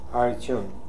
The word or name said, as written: Hariton